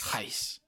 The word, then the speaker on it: Reis: No lo puedo pronunciar en portugués, Beto. – Haz de cuenta que se te atoró algo en la garganta y así es. – Reis.